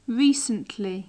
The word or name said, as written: recently